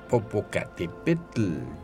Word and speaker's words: Popocatépetl: estamos de regreso en el espíritu de las montañas, hablando de una montaña muy importante, el Popocatépetl.